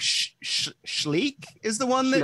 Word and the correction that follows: Schleich: Schleich, yeah. Schleich, okay. Or Schleek. – See, you're pronouncing it correctly.